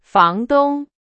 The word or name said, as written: 房东